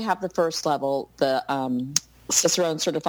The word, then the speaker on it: Cicerone: Explain what a Cicerone is, and am I saying it correct finally? – You are saying it correctly. You used to call it Chicharron, which I like too.